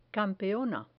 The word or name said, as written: campeona